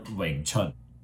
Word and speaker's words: Wing Chun: So first one I want to start off is how do we properly pronounce the term Wing Chun? – Yeah, just pronounce like Wing Chun, Wing Chun. Chun is like the spring. Chun Tin is spring.